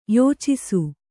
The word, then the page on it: ಯಾಚಿಸು: yācisu